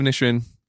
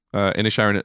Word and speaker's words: Inisherin: And then to rank them, I would put Banshees of Inisherin. Sorry, I keep saying that wrong. Inisherin as number one.